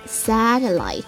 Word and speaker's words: satellite: 有了卫星，那你看到的数字地图啊，了解的气象信息啊，啥都来了 satellite，啥都来了 satellite。好，记住它的意思，我们来了解一下拼写 satellite， s a 读作 sa， t e 读作 t。 L-L-I 发音就是 light，那最后的话呢是一个 T-E 结尾，轻声的读一个 T， Satellite，卫星的意思 Satellite。